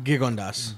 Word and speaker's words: Gigondas: So, well, so it turns out that these are pretty good, and we'll now, how did you pronounce Gigondas? – Gigondas. – Gigondas. – Gigondas. – Gigondas. Yeah. Okay, I'll remember that.